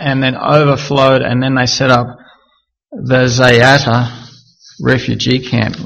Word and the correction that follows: Zaatari: Let me just get that pronunciation. Zaatari refugee camp in Jordan.